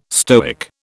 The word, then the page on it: Stoic: British English